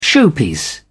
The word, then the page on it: showpiece: British English